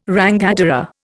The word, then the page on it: rangatira: Indian